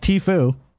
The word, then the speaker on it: Tfue: Tfue is pronounced Tfue.